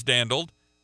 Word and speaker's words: dandled: How do you pronounce that word? And it was dandled.